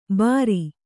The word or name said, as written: ಭಾರಿ